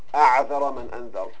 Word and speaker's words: أَعْذَرَ مَنْ أَنْذَرَ: And there's a famous proverb, Mathal, says أَعْذَرَ مَنْ أَنْذَرَ. أَعْذَرَ مَنْ أَنْذَرَ, it's commonly mispronounced by Arabs and by some speakers. Sometimes they say أُعْذِرَ مَنْ أُنْذِرَ, they mean anyone who has been warned will be excused.